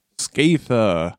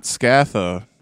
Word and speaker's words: Skaetha: Skaetha. And it is Skaetha, not Skatha. We have said Skatha many times in the past.